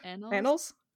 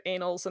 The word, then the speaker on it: annals: Annals? – Yeah. I've never known how to pronounce that word. I pronounced it anals until like high school.